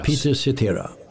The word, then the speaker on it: Peter Cetera: Peter Cetera.